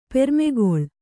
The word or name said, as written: ಪೆರ್ಮಗಳ್